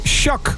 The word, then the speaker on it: SHQQ: Hoe spreek je dat nou uit, dat SHQQ? We waren er vorige week over eens. We waren het erover eens. Het zou Shaq moeten zijn. SHQQ, dat moet Shaq zijn.